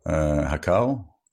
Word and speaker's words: Raquel: So, your mother, Raquel? Raquel, right? – That's actually Rachel or Raquel in Portuguese, Raquel. – Raquel, okay, so I didn't pronounce it right, Raquel, okay.